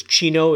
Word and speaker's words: CINO: The acronym is CINO, C-I-N-O. I like to kind of pronounce it as Chino. It kind of gives it that, you know, Italian sound to it.